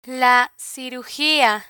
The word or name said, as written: la cirugia